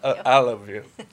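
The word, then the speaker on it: Allovue: The founder and CEO of Aluvu, if I pronounced that correctly. – Aluvu.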